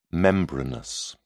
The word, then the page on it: membranous: British English